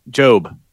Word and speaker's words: Job: And then this is when Jode. Am I saying his name right? Jode. – Job. J-O-B. – So it's a Job with the okay. – Yeah, like in the Bible.